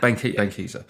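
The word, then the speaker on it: Benckiser: Was that Reckitt Ben Heiser? Is that my saying that right? – Ben Keiser.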